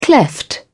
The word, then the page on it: cleft: British English